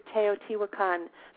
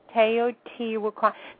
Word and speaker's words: Teotihuacan: We're going to Teotihuacan, Mexico. – Teotihuacan. Thank God you pronounced that, because when I saw that in the email, I went.